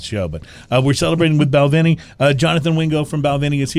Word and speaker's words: Balvenie: Balvenie.